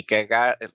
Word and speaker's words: Kierkegaard: É, é, é dinamarquês e nós optamos por não dizer em dinamarquês, diz, dizemos fazemos à nossa maneira aqui cagar e portanto é, se o ouvinte depois eh, se interessar pode até ver como é que será a pronúncia em dinamarquês.